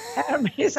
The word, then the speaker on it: Hermes: But on the flip side, I've also read that your favorite Greek god is, now I don't know if you would pronounce it Hermes or Hermes. – Hermes, unless you want to refer to the scarves.